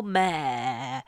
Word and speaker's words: Maher: So, Bill Maher, which is apparently how you say it,